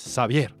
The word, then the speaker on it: Xavier: Emma Orbiler, Emmanuel Orbiler con su disco Xavier, ¿ok? Es Javier con X, pero se pronuncia como en francés, Xavier.